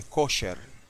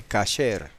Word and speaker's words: kasher: Y los americanos, que la mayoría son askenacitas, dicen kosher. Y es bueno conocer la palabra, ¿verdad? Porque está escrita así en muchos productos. Pero entre los sefarditas, que es la pronunciación que nosotros seguimos, se dice kasher, no kosher.